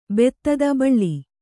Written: ಭೇದಿತ

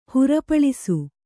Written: ಹುರುಪಳಿಸು

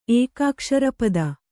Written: ಏಕಾಕ್ಷರಪದ

